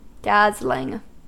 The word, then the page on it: dazzling: American English